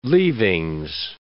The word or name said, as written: leavings